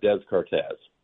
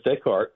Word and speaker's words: Descartes: And let's remind everyone that there are lots of S's in this French philosopher's name that aren't pronounced. – Yeah, if you look at his name, it looks like Descartes. But it's Descartes.